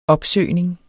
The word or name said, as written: opsøgning